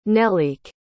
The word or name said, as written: nelleke